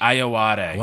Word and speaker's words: Ayoade: Other voices include Zazie Beetz, Craig Robinson, Anthony Ramos, and Richard Ayoade. How do you say Ayoade. – That? Ayoade.